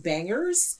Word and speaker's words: BNGRS: So this week, the Artist Spotlight is going to be on an artist collective out of LA called Bangers. And it's spelled B-N-G-R-S.